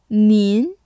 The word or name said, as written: 您